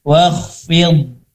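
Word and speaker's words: Wakhfid (وَاخْفِضْ): Wakhfid, bod, tebal ya. Setelah fa, kasroh, tipis, fi, ketemu huruf bod sukun, dibacanya tebal.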